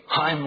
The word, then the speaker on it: Chaim: Hasa, the next word. Really, that same kind of pronunciation as Kumani or Chaim Weizmann. We don't even have that sound in the English.